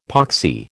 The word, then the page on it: poxy: American English